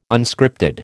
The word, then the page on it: Unscripted: American English